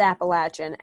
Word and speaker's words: Appalachian: It's pronounced Appalachian. Appalachian. Appalachian. Not the other way. Appalachian. I'm going to say it one more time. Appalachian.